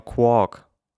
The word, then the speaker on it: QUOC: Ich sag mal Quark. So, Quark. Quark. Quark. Q-U-O-C.